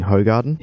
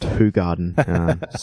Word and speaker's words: Hoegaarden: He's brought to our attention that last week when we were drinking beer, we mentioned we were drinking Hoegaarden. He's bravely corrected us by saying it's pronounced Hoegaarden.